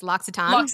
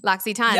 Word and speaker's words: L'Occitane: And I saw that you worked for I'm going to butcher this, L'Occitane. – L'Occitane.